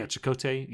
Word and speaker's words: Chakotay: Chakotay. – Yeah, Chakotay.